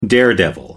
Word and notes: daredevil: American English

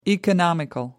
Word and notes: economical: American English

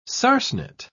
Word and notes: sarcenet: British English

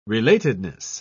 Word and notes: relatedness: British English

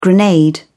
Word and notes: grenade: British English

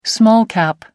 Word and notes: small cap: American English

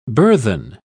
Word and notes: burthen: British English

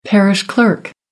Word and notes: parish clerk: American English

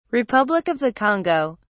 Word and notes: republic of the congo: British English

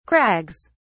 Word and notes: crags: British English

